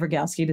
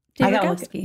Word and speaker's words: Rogalski: Speaking of crushes, how funny when Lane's like, do I want Dave Rogalski to see me? I keep saying his name wrong. How do you say it? – Rogalski. – You said it right.